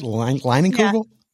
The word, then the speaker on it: Leinenkugel: Am I saying that right? Leinenkugel? – Yeah, okay.